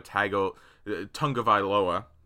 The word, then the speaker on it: Tagovailoa: The Chargers in this mock draft took him at six over to a Tagovailoa.